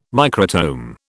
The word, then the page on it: microtome: British English